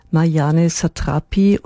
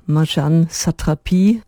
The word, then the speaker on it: Marjane Satrapi: Zunächst allerdings, Sie haben es vielleicht kürzlich in den Nachrichten gehört, Marianne Satrapi oder Marjane Satrapi auf Französisch ausgesprochen, Comiczeichnerin, Filmemacherin, Illustratorin von Kinderbüchern und Malerin, zuletzt wohnhaft in Paris, ist gestorben.